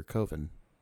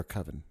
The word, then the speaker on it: Coven: In this movie, like his first credit is in 2000 for Coven. – Yeah. – Or Coven, sorry. – Coven. – I'm sorry, I got COVID on the brain.